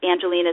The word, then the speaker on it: Angelina: and Angelina is spelled A-N-G-E-L-I-N-A, and then Carlton is spelled C-A-R-L-E-T-O-N.